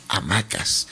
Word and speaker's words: Hamaques: Pero se pronuncia amaques. Claro, es que lo veo así, digo amaques. Uh-huh. Amacas.